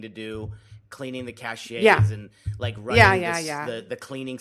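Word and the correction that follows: cache: And the other thing, did you just pronounce it cachet? – Cachet. – Isn't that it? – Part of France. – I think it's cash.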